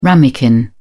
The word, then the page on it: ramekin: British English